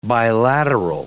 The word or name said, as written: bilateral